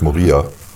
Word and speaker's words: Moria: Nee, es wird das äh, ist italienisch, Moria. Zweite Silbe wird betont.